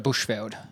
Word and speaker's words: Bushveld: Yeah, the Bushveld.